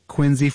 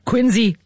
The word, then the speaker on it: Quincy: The sort of pronunciation idiosyncrasies of my current home state, I can't account for them, but I just know that they're real and people care. So Quincy, Massachusetts, and John Quincy Adams.